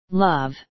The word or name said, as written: love